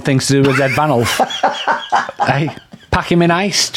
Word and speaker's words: Ranulf: We will replace Sir Randolph Fiennes Ranulf, not Randolph. – Ranulf. I said Ranulf. – Did you? – I don't think I pronounced the D then.